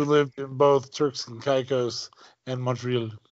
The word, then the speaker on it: Caicos: Turks and Caicos. Actually, it's pronounced Caicos.